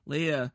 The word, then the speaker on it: Leia: Leia, I know how to say her name, Leia, training? – It was fun.